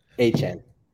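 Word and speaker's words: Achane: I've got Devon Achan. I'm not sure how he pronounced it. – Achan.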